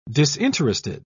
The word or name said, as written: disinterested